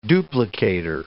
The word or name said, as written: duplicator